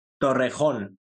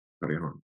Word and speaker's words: Torrejón: Torrejón. – Torrejón.